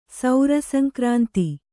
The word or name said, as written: ಸೌರಸಂಕ್ರಾಂತಿ